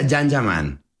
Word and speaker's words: Can Yaman: Se escribe Can Yaman, se pronuncia Yan Yaman.